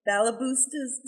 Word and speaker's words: Balaboosta's: Balabusta's Secret.